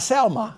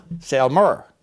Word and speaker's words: Selmer: Not Selma, Selmer, S-E-L-M-E-R.